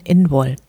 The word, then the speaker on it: Imbolg: Der keltische Name für Lichtmess wird Imbolg geschrieben und wird irisch ausgesprochen Inwol.